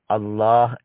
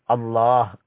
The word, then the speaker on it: الله: அல்லாஹ் என்ற பெயருடைய இறுதியில் ஆ என்ற எழுத்து இருக்கிறது. அந்த எழுத்து தெளிவாக மொழியப்பட வேண்டும், அல்லாஹ் என்று கூறப்பட வேண்டும். அப்போதுதான் அல்லாஹ் என்ற வார்த்தையை மொழியும் போது அதற்குரிய என்ன உரிமை இருக்கிறதோ அந்த உரிமையை பரிபூர்ணமாக நிறைவேற்றியவர்களாக நாம் ஆகுவோம். அது அல்லாமல் சிலர் அல்லா என்று கூறுவார்கள், சுபஹான் அல்லா, அல்ஹம்துலில்லா என்று கூறுவதை நாம் கேட்டிருப்போம்.